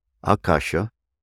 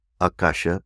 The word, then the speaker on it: Akasha: Akasha, A K A S H A, Akasha. Akasha, proper pronunciation, Akasha.